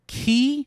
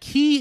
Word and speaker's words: χ: That key is with an X. It's spelled with an X. It is because that character that we look at that looks like an X is actually the Greek letter key.